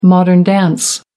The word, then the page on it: modern dance: American English